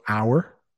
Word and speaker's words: hour: So hour, that's or, hour, or. So the 2 hours, O-U-R, and it's pronounced the same as if it had an H in front of it.